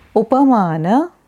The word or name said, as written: Upamana